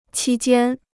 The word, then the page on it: 期间: qī jiān